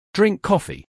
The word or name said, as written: Drink coffee